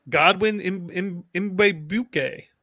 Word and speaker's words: Godwin Igwebuke: Do you have his name? – Godwin Igwebuke. – Igwebuke. – Yes.